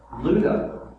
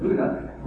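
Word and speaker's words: Lydda: As Peter traveled about the country, he went to visit the saints in Luda, how it's pronounced, which is crazy to me, but Luda, where he found a man named Aeneas, a paralytic, Aeneas, I think.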